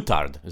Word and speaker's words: moutarde: Or moutarde is how you say it in French.